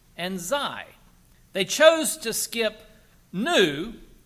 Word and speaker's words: Xi: And the other letter they skipped is Xi, which is spelled X-I, which is also the name of the leader of China, President Xi, X-I.